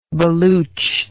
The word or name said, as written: baluch